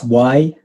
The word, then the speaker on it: Ywain: Yeah, so it's Ywain. And it's a Y because in Welsh, we pronounce a Y as an sound. So, and so the real pronunciation should be like Ywain.